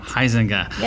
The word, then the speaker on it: Huizenga: Heisinger.